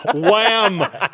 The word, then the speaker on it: Wham: Wham, Christmas. Oh, man. There are so many people that are like, wham. Look it up. YouTube it. W-A-M. Wham. Oh, trust H-A-M. – W-H-A-M. W-H-A-M. Yes. H is silent.